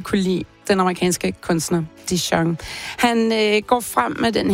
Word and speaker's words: Dijon: Det er den amerikanske kunstner Dijon. Og ja, det er navnet, du udtaler, ligesom din Dijon Senap.